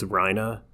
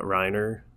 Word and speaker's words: Reiner: It's Reina, not Reiner.